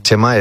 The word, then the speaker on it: Chema: Porque Chema es C-H-E-M-A, ¿no? Y entonces la forma de pronunciarlo es más casi como Chima.